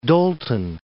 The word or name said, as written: Dolton